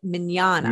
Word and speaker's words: Mignogna: I see Vic Magogna. How do you say his name? – Well, okay, he pronounces it Mignogna.